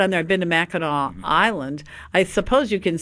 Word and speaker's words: Mackinac: Mackinac, spelled Mackinac, but pronounced Mackinac. That's sort of like you don't say Belzoni. Belzona. You don't say Mackinac.